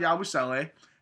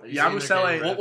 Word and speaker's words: Yabusele: Are you sure you're not butchering their name? – No, that's actually correct. I swear. So Yabusele...